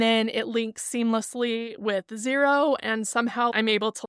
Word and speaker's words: Xero: I use the program Xero. It is spelled X-E-R-O, and that's an actual accounting program.